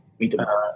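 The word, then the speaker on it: Miitomo: Like, do you pronounce it Mitmo or Mitmo? – Mitomo. I say Mitomo.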